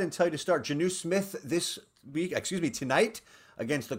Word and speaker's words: Jonnu: Yes, you got Jonnu Smith correct and go.